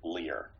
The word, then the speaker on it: Lear: Which is odd because the way my name is spelled, it should be pronounced Lair, but for some reason my family pronounces it Lear.